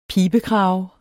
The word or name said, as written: pibekrave